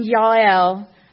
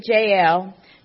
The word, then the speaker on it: Jael: It's the kind of story that we're entering into today as we look at Deborah and Yael or most pronounce it Jael, two risk taking women who are completely, to their core, women of the house.